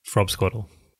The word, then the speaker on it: frobscottle: Or frobscottle, nothing but a whiz-popping great time. – It's Queen's breakfast but approaching frobscottle. Did I say that right? – You did.